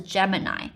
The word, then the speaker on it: Gemini: 那除了前面的 G E 变化之外呢，它的还有一个例外，后面的 M I N I 通常大家看看到就会想到 mini 那个单字，但是这个最后一个 I 字母它的念法其实是 I，所以是 Gemini。